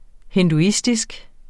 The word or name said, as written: hinduistisk